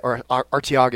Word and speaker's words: Artiaga: And Sal Artiglia was the guy that Artiaga is how you say his name, was the guy that first said, hey, there's a lot of great talent down here.